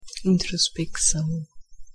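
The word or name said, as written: introspecção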